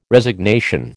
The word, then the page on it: Resignation: American English